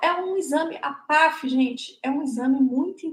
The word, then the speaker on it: PAAF: a gente chama de PAF. na verdade, são dois As, mas a gente acaba pronunciando como se só tivesse um A.